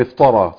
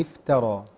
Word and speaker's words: افترى: الخطأ هنا في التاء. – يعني بعضهم بيقع في خطأ في نطق التاء، أي تاء. – تمام. – فمن افترى صح؟ – تمام. ولكن تمام. الصحيح طبعا إيه؟ ترقيق التاء.